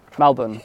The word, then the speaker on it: Melbourne: How should you say it? – Melbourne. – Melbourne. It's not Melbourne. – Everything. – It's not Melbourne. It's not quite right, I guess.